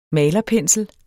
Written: malerpensel

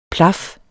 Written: plaf

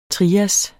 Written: trias

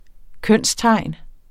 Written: kønstegn

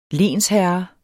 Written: lensherre

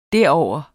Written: derover

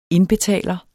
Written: indbetaler

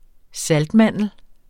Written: saltmandel

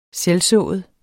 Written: selvsået

